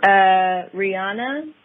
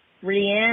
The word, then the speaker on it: Rihanna: Rihanna is Rihanna. – Not Rihanna. Rihanna. Oh, like the song.